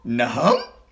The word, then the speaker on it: Nahum: The verse that I think they were talking about Mary Brown referencing is, the book is called, it's spelled N-A-H-U-M. – What? In the Bible? – Nahum.